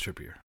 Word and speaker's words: Trippier: Trippier. Is that how you say it? Yeah, that's what he looks like. I mean, yeah, he's English, so it's Trippier for sure.